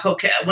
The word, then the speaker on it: coquette: Croquette is the food. But this is coquette, yes.